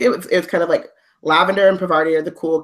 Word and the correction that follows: Parvati: Oh, for everyone listening, you can see my pinned tweet, but it's Parvati. So the first syllable is the strong one.